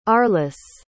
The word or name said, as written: arliss